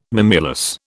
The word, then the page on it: mimulus: British English